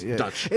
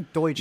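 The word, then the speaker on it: Deutch: I always mispronounce it, even though it's Dutch, and I know it's Dutch. – I always say Deutsch.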